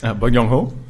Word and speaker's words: Bong Joon-ho: Bong Joon-ho? – Ja, genau, Bong Ho.